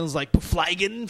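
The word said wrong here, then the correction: Byfuglien: Flygen. Like, could not pronounce the name. And all right, I'm already accepting my fate. But I have to look at his name Justin. – Again. Yes, and it's Byflin.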